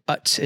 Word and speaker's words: Utz: No, so I guess it's pronounced Utz is the chip company in Pennsylvania.